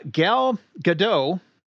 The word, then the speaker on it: Gadot: Uh, Gal Gadot is not pronounced Gal Gadot, it is Gal Gadot. Uh, more specifically, Gal Gadot, not Gal Gadot. The, the she specifies that the T is a softer T uh, but the T is pronounced, and it is not Godot, it is Godot.